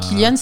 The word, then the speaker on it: Cillian: Normalement, Cillian, c'est un K.